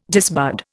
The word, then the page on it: disbud: Indian